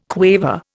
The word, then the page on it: quaver: Indian